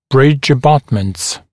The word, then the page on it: bridge abutments: [brɪʤ ə’bʌtmənts]; [бридж э’батмэнтс]